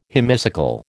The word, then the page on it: hemicycle: American English